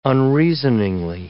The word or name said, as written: unreasoningly